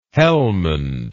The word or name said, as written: helmand